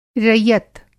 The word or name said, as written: रइअत